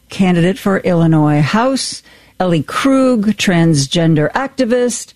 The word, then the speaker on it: Illinois: That's how you say it, Illinois. Just ask anybody.